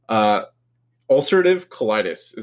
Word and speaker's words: ulcerative colitis: So, ulcerative colitis. Am I saying that? – Yes. Right? You're saying that right.